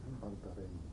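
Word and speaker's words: panta rhei: Pantarhej, že ano. Jestli to vyslovuje tak, jo? Jo? – Pantarhej. – To H se tam neříká. Je tam ale H psáno. H se nesmí říkat. Oni H neříkají.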